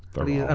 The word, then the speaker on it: Theron: It is Charlize Theron, you're right. – Okay. – Right. Charlize Theron? – Theron.